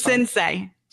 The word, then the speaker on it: Sensei: Am I pronouncing that correct? Because I always butcher it. – Sensei. It's Sensei.